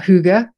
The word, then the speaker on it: Hygge: We talked about your book, um, all about Hygge. Am I saying it right? I always you, I have, you nailed it. Well done.